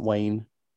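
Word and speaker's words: Wain: Say you pronounce it Wayne.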